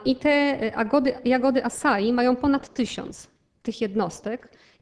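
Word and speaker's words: açaí: Pisze się acai, ale czyta się acai.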